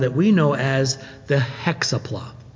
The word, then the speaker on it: Hexapla: I've heard it pronounced Hexapla. That's the first way I've ever heard it said. So please permit me that pronunciation of the word Hexapla. I think we're going to emphasize the six.